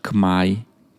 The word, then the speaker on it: Khmer: Cerco di chiedere all'autista come si dice ciao in Khmer, che si pronuncia Khmai, che è la lingua che si parla in Cambogia.